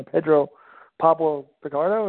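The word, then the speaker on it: Pedro Pablo Pichardo: Pedro Pablo Picardo, is that right, John? – Yep.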